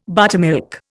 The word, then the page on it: buttermilk: Indian